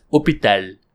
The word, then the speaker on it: hôpital: Fun fact, hospital is spelled the same in French and in English, but it's pronounced in the French as hôpital,